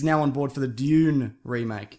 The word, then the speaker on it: Dune: Dune. It's Dune. Sand Dune. – June. – Yeah, like the month.